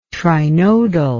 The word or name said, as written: trinodal